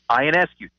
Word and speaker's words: Ionescu: Sabrina Ionescu. – Ionescu, thank you.